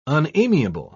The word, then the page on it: unamiable: British English